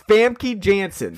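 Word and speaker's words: Famke Janssen: It's Famke Jensen. – Famke? – Yes.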